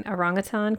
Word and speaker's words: orangutan: According to Dr. Brute Galdikas, and I really hope I'm not saying her name wrong, but I know I'm saying orangutan correctly, and she'll appreciate that because she did say it's a little irritating that people don't ever say it right.